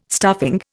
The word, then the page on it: stuffing: Indian